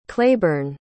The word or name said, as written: claiborn